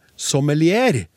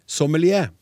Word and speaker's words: sommelier: Det var Klaus som brakte temaet på banen, og han brukte det franske ordet sommelier, og han uttalte det sommelier. Med r la jeg himmelfallen merke til. Jeg ventet i åndeløs spenning på hvordan vinekspert og språkforsker Husby ville si ordet, om han ville korrigere Unges Onsdag, men jommen sa ikke også han sommelier. Ordet står i Det norske akademis ordbok, og uttalen er angitt til sommelier.